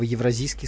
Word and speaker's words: евразийский: Слушайте, коллега, смотрю, вам никак не произнести слово «евразийский» слитно, так быстро. «Евразийский». Вы его делите почему-то.